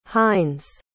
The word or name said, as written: hines